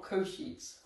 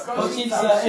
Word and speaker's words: Košice: You said it wrong, it's Košice, not Košice. – Okay, thanks. – Košice, it's Košice.